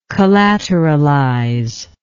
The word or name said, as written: collateralization